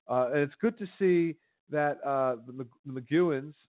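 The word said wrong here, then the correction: McGuigan: and uh, uh, the McGuigans, sorry, let me not uh, butcher that, the McGuigans uh, Cyclone Promotions uh, you know, they've pushed Taylor, and I know Taylor's not young.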